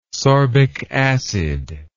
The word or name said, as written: sorbic acid